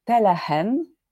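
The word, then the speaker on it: Tellegen: Absolutny zachwyt to jest Podobno tak się wymawia to nazwisko, bo to jest Holender, ten Telehen, Telegen się pisze.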